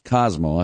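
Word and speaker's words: Cosimo: Now, it was spelled with an I but pronounced Cosmo. I found that out.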